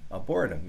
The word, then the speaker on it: Arboretum: I always get Arboretum, Arboretum, Arboretum. Yeah, that's how it's pronounced.